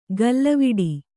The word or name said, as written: ಗಲ್ಲವಿಡಿ